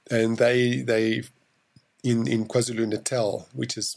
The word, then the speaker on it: KwaZulu: Um, talk about uh, is that pronounced KwaZulu? Yes, KwaZulu Natal.